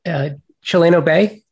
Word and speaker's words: Chileno Bay: There is a Chileno Bay, did I pronounce that correctly? – Yes.